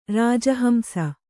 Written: ರಾಜಹಂಸಿ

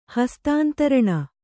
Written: ಹಸ್ತಾಂತರಣ

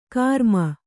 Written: ಗರಮ್

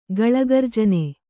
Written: ಗಳಗರ್ಜನ

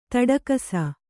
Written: ತೊಡಕಿಸು

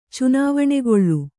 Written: ಚುನಾವಣೆಗೊಳ್ಳು

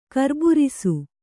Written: ಕರ್ಬುರಿಸು

